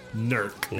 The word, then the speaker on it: Newark: Or if you're from there, NERC. – NERC. – That's how they pronounce it.